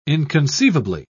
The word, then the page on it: inconceivably: British English